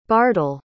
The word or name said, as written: bardol